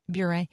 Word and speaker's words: Bure: Okay, Candace Cameron Bure? – Bure. – Am I? – Yep. – Bure. – That's correct.